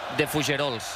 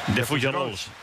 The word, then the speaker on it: De Fougerolles: ¿Cómo pronunciáis al central, al 4 de Canadá? Porque lo habéis hecho igual, Fermín y tú. ¿Cómo es, Fermín? – De Fougerolles. – De Fougerolles.